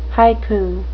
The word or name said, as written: haiku